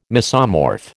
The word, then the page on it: mesomorph: American English